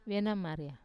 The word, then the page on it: venamaria: Wamesa